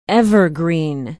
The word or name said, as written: Evergreen